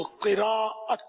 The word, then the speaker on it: قراءت: وہ قراءت، صحیح تلفظ ہے قراءت، قراءت عوام بولتے ہیں،